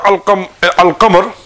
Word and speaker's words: القمر: The reason being is that in the word al-qamar, the lam is pronounced, isn't it? – Yeah.